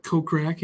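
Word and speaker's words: Kokrak: Cup defending champion Jason Cockrack. Is that how you say it? – Cockrack? I think it's Cockrack, yeah.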